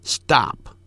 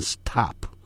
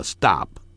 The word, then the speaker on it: stop: 节省 ,sp, 但在 s 后头那个 p 要发成 b 的音，就好像 s 后头有 t, 那个 t 那个所谓英文字母啊，当音标的时候在 s 后头要念成 d, stop, 不要念成 stop, 停止叫 stop。